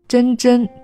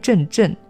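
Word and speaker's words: 蓁蓁: 遗儿子孙真真兮，真真注意读音，不念阵阵，真真是茂盛的样子。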